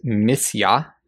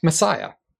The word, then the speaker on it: Messiah: The word for anointed here, as I mentioned earlier, is missia, or as we English folk would pronounce it, Messiah.